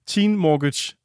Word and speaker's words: Teen Mortgage: Teen Mortgage. Er det ikke sådan, man siger det? Det er som et lån i et hus, I ved.